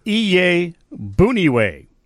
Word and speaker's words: Iyiegbuniwe: It's Iye Buniwe, spelled I-Y-I-E-G-B-U-N-I-W-E.